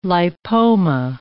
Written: lipoma

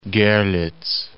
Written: Gorlitz

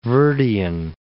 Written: Cape Verdean

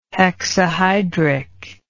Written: hexahydric

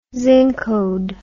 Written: zincode

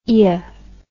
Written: ea

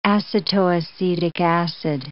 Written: acetoacetic acid

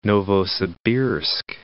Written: Novosibirsk

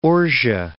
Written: Ohre

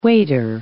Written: waiter

